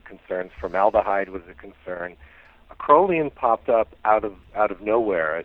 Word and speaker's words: acrolein: Is it, and I may get the pronunciation wrong, acrolein? – Some people say acrolein, some people say acrolein.